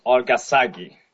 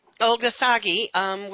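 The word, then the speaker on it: Olorgesailie: And being located at the site about an hour south at, correct my pronunciation. – southwest of Nairobi, a place called Olgasagi. – Olgasagi, which it seems to be a rather natural center of a meeting place.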